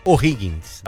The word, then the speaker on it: O'Higgins: O'Higgins. – Aí. – Falei certo? – Falou.